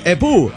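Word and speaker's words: époux: Y se dice epu, esposo. Se escribe epous, pero se dice epu.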